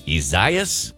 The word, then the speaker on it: Isaias: Isaias? It's like Isaiah with an S at the end of it, and it's tripping me up a little bit.